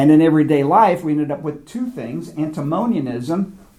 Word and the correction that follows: antinomianism: and did I pronounce it correct? – Antinomianism. – I think I said it wrong. Antinomianism and asceticism.